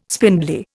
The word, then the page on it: spindly: Indian